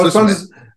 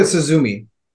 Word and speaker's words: Suzume: No, Suzume. – I was pronouncing it Suzumi. – Oh, yeah, you were quite wrong. Okay.